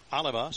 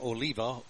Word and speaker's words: Oliva: Oliva spells it like Oliva, O-L-I-V-A, but he pronounces it Oliva.